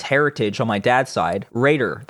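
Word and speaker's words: Reder: And why it's still pronounced Raider.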